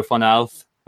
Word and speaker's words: Fornals: Um, a big signing, Pablo Fornals.